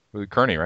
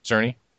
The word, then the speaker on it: Cerny: Marble Madness was designed by Mark Cerny, right? – Cerny. – Oops, got his name wrong twice.